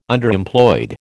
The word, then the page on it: Underemployed: American English